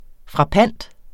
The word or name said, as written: frappant